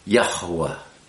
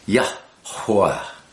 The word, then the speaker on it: Yahuwah: But here we have Yod He Waw He. That's Yah. Hey is a breath, ha, yah, and then wa, hey, wah, yah, hua, and you'll hear me pronouncing that hey in the center with a little more emphasis, Yahuwah, Yahuwah. It's two syllables really, yah, and hua, and you blow the hua, so that there's almost a little syllable in front of the second syllable.